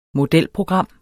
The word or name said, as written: modelprogram